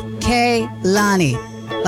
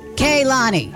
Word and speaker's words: Kehlani: But also in the UK, everybody mispronunciates her name. Some say Kehlani, some say Kehlani. And I'm like, no, it is Kehlani, like K-A-Y, Lani. But, you know, that's not how you spell it. That's just how you say it.